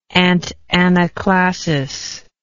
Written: antanaclasis

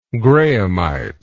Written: grahamite

